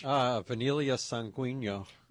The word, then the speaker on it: Vanilla sanguigno: But I just love that Australian finger lime, and I also like the blood orange that – and you need to pronounce that for me. the blood orange that is the vanilla blood orange? – Vanilla sanguigno.